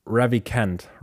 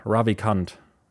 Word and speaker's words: Ravikant: Und dann noch zusätzlich habe ich noch ein, eine Folge von Joe Rogans Podcast gehört mit, oh, jetzt muss ich den Namen richtig aussprechen, Naval Ravi, Ravikant, Ravikant, R-A-V-I-K-A-N-T.